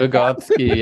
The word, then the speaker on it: Vygotsky: is it Vygotsky? Is that correct? Did I say that correctly? – Yes, Vygotsky, yes.